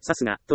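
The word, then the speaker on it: 流石: 私は、さすがという読みを知らないまま、覚えようとしていました。これがいけなかった。さすがという漢字は、さすがと読みます。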